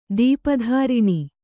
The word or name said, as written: ದೀಪಧಾರಿಣಿ